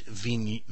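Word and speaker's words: Vignoles: And I said, I like this one in this blue bottle, and I didn't know how to pronounce it. Now I do. It's Vignol.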